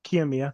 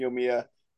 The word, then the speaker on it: Kiyomiya: And is it Kiyomiya? Kiyomiya? I don't actually know how to pronounce Kiyomiya. – it. Kiyomiya.